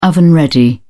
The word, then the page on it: oven-ready: British English